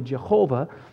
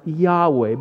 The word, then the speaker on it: Yahweh: the word was not actually Jehovah, but it was to be pronounced Yahweh. But some of us are in the habit of saying Jehovah. So this morning, I'm going to stick with that pronunciation, Jehovah.